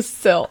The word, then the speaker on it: sill: It's a window sill. Okay. Nope, we're not going to argue about this. Go. Keep going. – Sill? – Window. Sill. S-I-L-L. Sill. Like will. Rhymes with will.